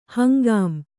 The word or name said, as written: ಹಂಗಾಂ